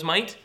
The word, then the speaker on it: mite: I prefer the name the widow's mite instead of the widow's mite, but that's because spelling, they're the same word as far as phonetically, but it's called often the widow's mite, M-I-T-E, which is what?